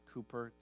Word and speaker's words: Cowper: It's spelled, it looks like Cowper, but most people pronounce it Cooper, C-O-W-P-E-R.